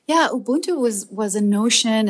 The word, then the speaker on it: Ubuntu: And speaking of which, shifting gears a little bit, I saw your TED Talk that you gave in Austria about Ubuntu.